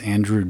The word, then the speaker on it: Andruw: I think the only other player younger than him to have three RBIs in a World Series game is Andruw Jones, spelled A-N-D-R-U, the Jones from the Braves in the 90s. – Spelled like it sounds.